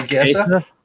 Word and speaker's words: Chiesa: Oh, Chiesa.